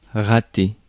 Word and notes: botch: /bɒt͡ʃ/; /bɑt͡ʃ/; /bɒʧ/

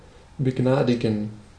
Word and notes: pardonner: France; [paʁ.dɔ.ne]

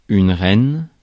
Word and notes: reine: /ʁɛn/

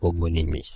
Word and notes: homonymie: /ɔ.mɔ.ni.mi/

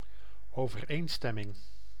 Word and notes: accordance: [ə.ˈkɔr.dᵊnts]; /ə.ˈkɔɹd.əns/; /ə.ˈkɔr.dᵊns/

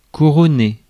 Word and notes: couronner: [ku.ʁɔ.ne]; France

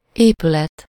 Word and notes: bâtiment: France; [bɑ.ti.mɑ̃]

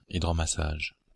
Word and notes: hydromassage: Belgique (Brabant wallon); [idʁɔmasaːʒ]

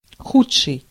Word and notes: inferior: /ɪnˈfɪ(ə)ɹiɚ/

